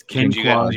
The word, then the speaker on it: Quinquagenario: It's Ken, like Barbie and Ken. Qua, like the Quan. – Oh, okay. – Genario. – So okay. – King Quagenario. – It's Ken Quagenario.